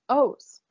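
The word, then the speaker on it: Öz: So it's actually meant to be pronounced O's. Oz. It's like a very, like, I don't know. How, yeah. Anyway, Mehmet Oz. That's how his name should be said in Turkish.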